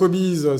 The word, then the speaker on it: Obiz: donc Obis, ça s'écrit O-B-I-Z, mais ça fait référence au Obis, H-O-B-U-S, d et nous sommes en co-créateur de fidélité.